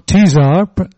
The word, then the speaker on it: Tesar: And John Tesar, spelled T-E-S-A-R, but pronounced as though he's involved with beverages rather than with food, decided he would write his first book on the subject of steakhouses, but steakhouses with a difference.